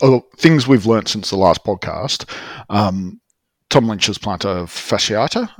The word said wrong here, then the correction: fasciitis: I think that's how you pronounce it. – Fasciitis, nice.